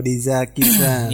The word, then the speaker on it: Deeza Kitchen: Oh, Diza Kitchen. – Iya, ejaannya DEEZA Kitchen. Kitchen-nya bahasa Inggris ya.